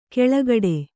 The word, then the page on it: ಕೆಳಗಡೆ: keḷagaḍe